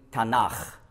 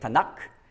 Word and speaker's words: Tanach: Und da gibt es eben Tanach. Warum nicht Tanak? Ganz einfach, wenn man Hebräisch lernt, muss man wissen, dass gewisse Buchstaben, wenn ein Vokal vorausgeht, die werden die weich.